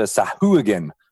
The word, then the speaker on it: Sahuagin: It's got the face of a Sahuagin. That's how you pronounce it.